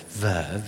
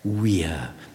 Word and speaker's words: vir: Because if you think about the first syllable of that word, vir, V-I-R, it is the Latin word for man in the masculine sense. Vir, or as my old Latin master used to say, weir, but he was a bit weird.